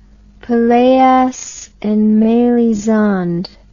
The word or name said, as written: Pelleas and Melisande